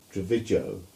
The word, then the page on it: Trevigowe: English